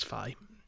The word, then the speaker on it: Fi: Apparently the English language and Japanese language narration in Hyrule Warriors says Fi.